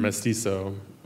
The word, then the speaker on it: mestizo: And there's such a lack of language around what it means to be mixed, or like in The Curse, you call it mestizo. Is that how you say it? – So the word, it's a Mexican word, mm-hmm. mestizo, which literally means mixed, M-E-S-T-I-Z-O, yeah.